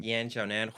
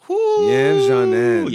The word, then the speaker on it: Yan Xiaonan: Yan Xiaonan. – Yan Xiaonan.